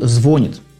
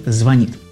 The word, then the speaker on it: звонит: Я помню, что когда 15 лет назад пришел сюда еще на радио Теос работать, я даже говорил «звонит». Меня за это долго очень били, и я понял, что правильно говорить «звонит».